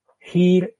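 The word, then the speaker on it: Hîr: Eh, se suele decir que es un acento extra largo, propio del sindarin, o sea, perdón, vocal extra larga, o también eh, vocal larga de monosílabos. Si os fijáis aquí, solamente aparece en sindarin en palabras de una sola sílaba. Hir, in, din, Gir es señor.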